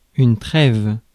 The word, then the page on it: trêve: France; /tʁɛv/